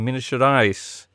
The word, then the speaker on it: Minas Gerais: They took on June 29th, an amateur USA side in Belo Horizonte, in Minas Gerais.